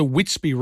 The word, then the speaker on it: Witsby: Now, this is a Witsby range. So it's W-I-T-S-B-B, but it's pronounced Witsby.